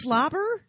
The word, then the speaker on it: Swlabr: We should say, it's Slobber? – It's Slobber, but it's spelled slobber. S-W-L-A-B-R.